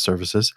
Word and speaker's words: svcs: which is short for Services um, and pronounced Services.